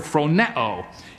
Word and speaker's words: phroneo: The word mind there is a Greek word which is pronounced in the English by the word phroneo.